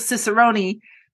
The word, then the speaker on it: Cicerone: If Russell Cicerone, which doesn't rhyme with minestrone, but it's the same pronunciation.